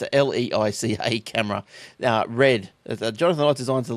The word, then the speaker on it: Leica: Now, I know I'm not going to pronounce this right, but it's a leash. Leica. What is it, sorry? – Leica. – Leica. – Leica. – Leica. And, yeah. – Like a glove?